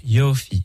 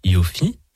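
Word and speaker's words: Yofi (יופי): Donc voilà, Yofi, c'est, et je dis bien Yofi, et pas Yofi, ouais, avec Miller L, avec l'accent tonique sur la première syllabe.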